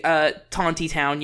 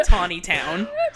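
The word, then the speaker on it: Tontitown: So it is supposed to be Tauntytown, yes. But everyone around here... calls it Tawny Town.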